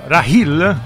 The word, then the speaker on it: Rahil: Ed eccoci qua mentre ascoltiamo ancora in sottofondo il brano Rahil, così si pronuncia, l'ho detto, esatto, bene, con la, questa H aspirata.